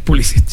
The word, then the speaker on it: Pulisic: Así bien pronunciado, Pulisic.